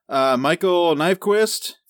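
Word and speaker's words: Michael Nyqvist: Michael Nyquist.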